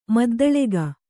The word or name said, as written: ಮದ್ದಳೆಗ